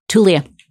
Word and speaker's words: Tulia: The first thing you talk about is Tulia. Is that how you pronounce? – Tulia.